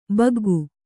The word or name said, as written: ಬಗ್ಗು